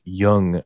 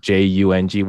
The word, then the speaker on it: Jung: What is if I was German, then that is that the most accurate pronunciation of my last name in German culture. But then at the same time, J U N G, if you're Korean, then that's actually Jung.